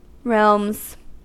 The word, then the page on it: realms: American English